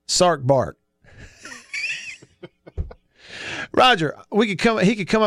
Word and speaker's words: Sark: Sark rhymes with bark.